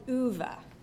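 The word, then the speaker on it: Ove: A man called Uwe. That's the pronunciation. Uwe.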